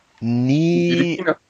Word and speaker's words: Ntilikina: Also das T in seinem Namen ist silent, wie man so schön sagt. Das wird nicht ausgesprochen. Und der Name ist insofern dann Frank Ntilikina.